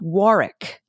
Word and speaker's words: Warwick: The second W in Warwick is not pronounced. Think Warwick.